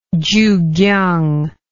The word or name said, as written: chukiang